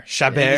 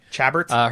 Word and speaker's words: Chabert: Chabert is how I'm going to pronounce it because it is French. – Chabert.